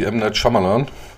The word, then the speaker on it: M. Night Shyamalan: Das Helle ist immer wiederkehrend bei uns wie M. Night Shyamalan.